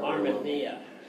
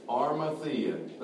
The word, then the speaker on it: Arimathea: I can't pronounce that. – Arimathea. – What's that? – Arimathea. – There we go.